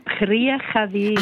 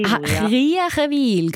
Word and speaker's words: Kriechenwil: Und jetzt darf ich Ihnen vorstellen, Kanton Bern die Hai in Kirchenwil. Habe ich das richtig gesagt? – Ja, Kriechenwil. – Kriechenwil.